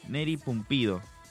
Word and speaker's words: Neri Pumpido: A ver si lo estoy pronunciando bien, Guille. Neri Pumpido. – Sí, ¿está bien dicho? – Es, sí.